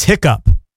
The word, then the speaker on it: hiccough: So the question was, what is the only word in the English language that ends in O-U-G-H and is pronounced P? So the O-U-G-H is pronounced P. Apparently it's hiccup. H-I-C-C-O-U-G-H. It's an older spelling of the current spelling of hiccup, which is H-I-C-C-U-P.